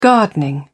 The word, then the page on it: gardening: British English